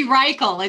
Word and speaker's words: Reichel: It's actually Reichel. It's Reichel. Reichel. Rhymes with Michael.